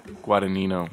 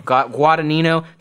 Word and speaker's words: Guadagnino: Guadagnino. – Guadagnino to direct Rooney Mara as Audrey Hepburn in a biopic for Apple TV.